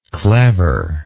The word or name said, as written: cleverishly